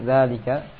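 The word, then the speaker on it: zalika (ذلك): Setelah kita mengetahui pada pelajaran yang telah lalu ada isim isyarah untuk jauh zalika. Nah. Isim isyarah yang jauh, kata tunjuk jauh zalika. Nah, za ditulis pendek, dibaca panjang. Nah, tulisnya pendek saja, za. Nah, dibacanya panjang, zalika.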